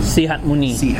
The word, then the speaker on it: Sihamoni: Sihamoni.